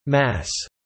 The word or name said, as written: mass